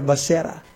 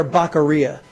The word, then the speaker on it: Becerra: The loudmouth leftist Latino is running out there, a guy by the name of Javier Becerra, or who Joe Biden called Javier Baccaria.